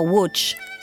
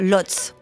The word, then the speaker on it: Łódź: Arthur Rubinstein voit le jour le 28 janvier 1887 à Łódź, C'est ainsi que se prononce le nom de cette ville en polonais, mais j'espère que vous me pardonnerez de le prononcer à la française, comme le faisait d'ailleurs Rubinstein lui-même, c'est-à-dire Lodz.